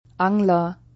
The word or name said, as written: Angler